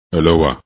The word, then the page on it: aloha: english